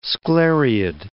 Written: sclereid

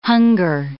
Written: hunger

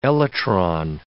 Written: elytron

